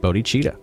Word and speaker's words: Bodhicitta: So it's kind of like put it together like two words. Bodhi, BodhiCitta. And that's pronounced Bodachita.